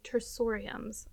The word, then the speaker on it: tersoriums: But anyway, so yeah, tersoriums. I said that wrong originally. Tersoriums are those sponges.